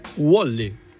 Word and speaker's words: Wally: È Wally. U-O. – Così come. Come lo mangi, lo pronunci.